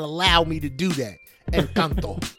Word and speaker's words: Encanto: It's Encanto. Not Encanto. Encanto. – My bad. I said like an American.